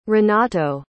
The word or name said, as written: renato